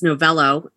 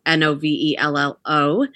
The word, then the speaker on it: Novello: For me, I put out Facebook and on Instagram, my handle Liz Novello, Novello. – Everybody's Novella.